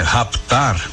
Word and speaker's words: raptar: Raptar, as pessoas dizem que esse pé é mudo, mas como eu expliquei, esse pé não é mudo, ele é lido em português, né?